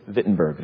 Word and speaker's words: Wittenberg: And so, yes, when you see a German name and there's a W there, it's not Wittenberg, it's Wittenberg. They pronounce the W's as V's.